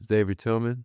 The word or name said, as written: Xavier Tillman